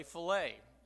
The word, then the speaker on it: fillet: And by the way, we're going to see a word here in the text that it's spelled F-I-L-L-E-T. If you're cooking and you see that word, it's pronounced the French way, filet. But if you're dealing with woodworking or metal or drafting or welding, it's pronounced fillet.